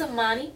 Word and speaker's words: Imani: My name is Imani, or Imani Aaliyah.